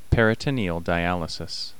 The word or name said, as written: peritoneal dialysis